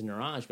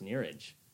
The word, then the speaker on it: Neeraj: And I was like, you know, so it's like, and my first name is Neeraj, but actually it's pronounced Neeraj.